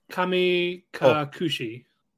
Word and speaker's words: Kami Kakushi: Uh, Kai to Otome to Kami Kakushi. Oh. Kuchi. You said Kuchi. – Kami Kakushi.